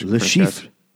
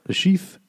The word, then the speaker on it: Le Chiffre: How do you say that really French? – Le Chiffre. – Le Chiffre? – Le Chiffre. – The R at the end is like so slight and so like just back of the throat.